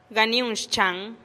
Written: ganiun xchan

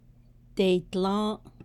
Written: deiidlą́ą́’

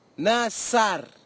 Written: nasar

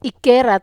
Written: kerat

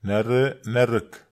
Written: nare

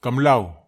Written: kamlau

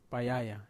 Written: payaya

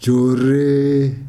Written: joree

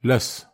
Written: las

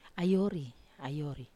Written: ayori

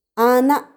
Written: ana

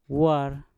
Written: war

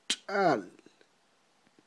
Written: tx'a'l

